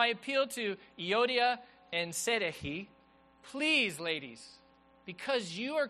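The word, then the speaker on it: Syntyche: In fact, Paul talks about two ladies in the church, and here's how you pronounce their names. It's Euodia and Sedehi. That's how you actually pronounce these names.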